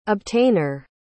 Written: obtainer

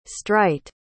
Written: streit

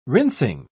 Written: rinsing

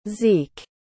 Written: zeke

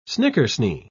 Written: snickersnee

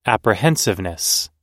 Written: apprehensiveness

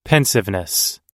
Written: pensiveness

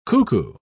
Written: cuckoo

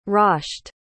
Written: rasht

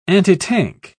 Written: antitank

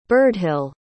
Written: birdhill

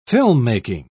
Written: filmmaking

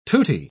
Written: tutti

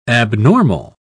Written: abnormal

